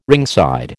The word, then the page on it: ringside: American English